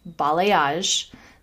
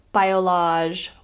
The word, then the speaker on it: balayage: And by the way, that's how you say it. It's balayage, not biolage, balayage, balayage, balayage.